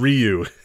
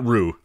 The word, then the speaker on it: Ryu: It's Ryu. – Is that Ru? – It's not Ru.